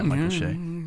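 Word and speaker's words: Michael Che: Shea? Is that how you say it? Yeah, Michael Shea.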